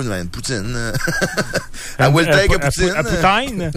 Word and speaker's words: poutine: C'est comment ça se prononce, poutine? Pour poutine. – Poutine. – Ah oui, poutine.